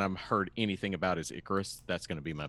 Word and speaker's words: Icarus: Is that Icarus or is that how you Icarus. – Pronounce Icarus? Boy.